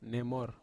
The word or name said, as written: nemor